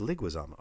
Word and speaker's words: Leguizamo: It's pronounced Liguizamo.